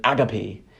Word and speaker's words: agape: And the last love he talks about, the fourth love, is agape, or as he pronounces it, agape.